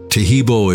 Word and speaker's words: Tahibo: Tahibo is spelled T-A-H-I-B-O. E-E-B, like boy, O, then the word T, and then the word club.